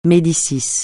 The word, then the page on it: Médicis: francais